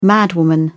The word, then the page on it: madwoman: British English